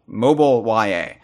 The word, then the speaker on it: MOBYA: They found files for it in Mario Kart Tour as MOBYA. The MOB, you might think it's pronounced Mob YA. It's like, what does the mob have to do with this? But it's actually mobile. Mobile YA. The mobile rendition of Yoshi's Island has been found by data miners, references to it.